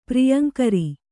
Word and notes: ಪ್ರಿಯಂಕರಿ: Kannada

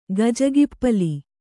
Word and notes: ಗಜಗಿಪ್ಪಲಿ: kannada

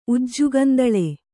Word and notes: ಉಜ್ಜುಗಂದಳೆ: Kannada

